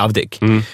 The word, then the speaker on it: Avdic: Och så här, är det Avdic eller Avdik? Då sa hon så här, men det är Avdik.